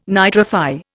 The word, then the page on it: nitrify: Indian